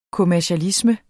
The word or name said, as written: kommercialisme